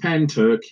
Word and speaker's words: Pentyrch: The next one I have on the list is the Penrich incident. Am I pronouncing that correct? – You say it's pronounced Pen Turk. It's just like pen you write with, and Turk, Pen Turk.